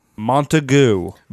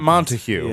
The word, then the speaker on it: Montagu: Montagu. – I don't know. Not Montague. Yeah. – No, Montagu. – Are you sure? – I listened to the French pronunciation. And it's Montagu.